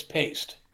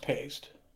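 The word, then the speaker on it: Paiste: And they said that their last name is Paste. Now, people say it both ways, you know. But in Europe, it's pronounced Paste.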